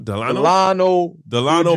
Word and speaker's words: Delano: What's how you pronounce his first name again? Delano? – Delano Banton.